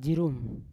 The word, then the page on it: jirum: Yawa